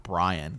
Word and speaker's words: Brienne: Like things like he almost calls her like, it's almost like Brian, the way he pronounces Brienne of Tarth.